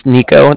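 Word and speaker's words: Niko: Alright, my name's Nico, that's N-I-K-O, mm-hmm. – And then the Bank of Cardiff is spelled C-A-R, D as in dog, I, F as in Frank, so it's like car and then different.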